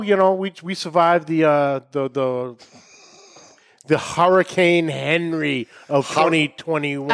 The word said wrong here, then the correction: Henri: I believe it's Henri. – Oh, what am I? – Really? – How it's spelled. – Oh, yeah, same. I look at like, oh, Henry. And then I hear the news reporter's like, Henri.